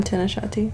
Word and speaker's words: Tanashati: Hi, I'm Tanashati.